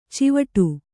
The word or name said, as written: ಚಾವಡಿ